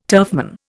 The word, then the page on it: turfman: Indian